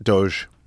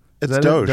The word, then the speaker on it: Doge: How do you say it? – Doge. – Does anybody really know how to say it? Doggy coin? – It's Doge. – Doge? – Yeah, it's Dogecoin.